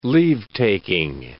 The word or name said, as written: leave-taking